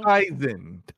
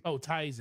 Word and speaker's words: Tizen: Tizen. There you go. – Tizen.